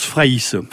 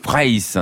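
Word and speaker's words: Fraysse: Oui, alors moi je prononce Fraisse. – Fraisse, hein, ouais.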